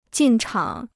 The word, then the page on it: 进场: jìn chǎng